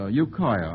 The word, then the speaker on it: Ukiah: Jesse Duell of Ukiah, I hope I'm pronouncing that correctly, California. I certainly am pronouncing that correctly. Ukiah, I'm informed, sends this one in.